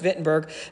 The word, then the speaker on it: Wittenberg: Wittenberg, Germany, pronounced Wittenberg.